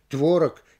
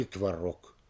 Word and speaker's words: творог: До сих пор никто не может с уверенностью сказать, как будет правильнее произнести мышление или мышление, иначе или иначе, творог или творог.